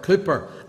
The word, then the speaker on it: Cowper: But one of the most touching aspects of John Newton's life was his tender care for the very famous English poet William Cowper, or Cooper, as some people pronounce it, probably more correctly than the way we pronounce it.